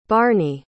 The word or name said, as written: barny